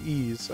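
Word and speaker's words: Ys: Where's the name Ease come from? – because it's because it's pronounced as Ease and spelled with like a Y.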